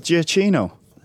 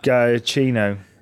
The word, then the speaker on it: Giacchino: Giacchino. – That's it. That's close, wasn't I? – Well, no. You said Gambino, and then you said I'm not going to even attempt to pronounce that. – Giacchino.